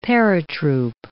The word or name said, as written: paratroop